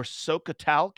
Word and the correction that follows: Scoia'tael: Did I pronounce that right? DBN Sokotal. – A scoia'tael. – Scoia'tael.